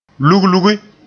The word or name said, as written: luglugi